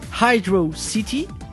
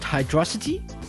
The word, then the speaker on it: Hydrocity: Opus Science says it's pronounced Hydro City, not Hydrosity.